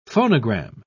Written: phonogram

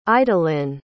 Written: idaline

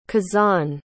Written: kazane

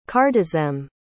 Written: cardizem

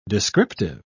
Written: descriptive

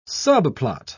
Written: subplot

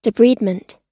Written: debridement

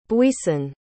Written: buisson

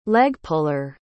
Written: leg-puller

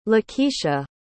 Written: Lakeisha

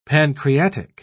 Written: pancreatic

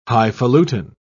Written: highfalutin

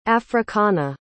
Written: africana

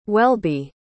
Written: Welby

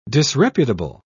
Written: disreputable

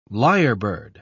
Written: lyrebird

